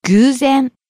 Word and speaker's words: 偶然: 偶然. – Coincidence. By chance. – 偶然. 偶然. – Starting low, rising on the second syllable. う. Staying high.